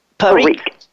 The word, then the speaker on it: Parikh: Parikh. – I did. – Yeah, it's actually Indian, so my name is actually pronounced Devika Parikh, but I say Devika Parikh because my mother was African American.